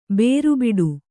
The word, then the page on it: ಬೇರುಬಿಡು: bēru biḍu